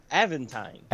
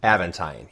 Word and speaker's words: Aventine: Is that how you pronounce it? Evelyn? – No. – It's like, once it came out of my mouth, I was like, that's not right. Aventine. – Aventine, yes.